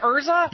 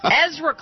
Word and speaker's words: Ezra: Did I say Urza? – You said Urza to another reporter. – Oh, jeez. Ezra Klein.